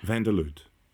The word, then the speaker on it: Vanderloot: I think it's pronounced Vanderloot. It's Dutch. – Vanderloot.